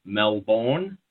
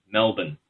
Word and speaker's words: Melbourne: So the southern capital, which is where the bushfires are happening at the moment, you guys say Melbourne. It's just Melbourne, but M-A-L-B-I-N, Melbourne.